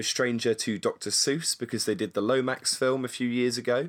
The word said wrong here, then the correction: Lorax: Is it the Lomax? Am I saying that right? – It's the Lorax. – Lorax. Ah. Lorax film.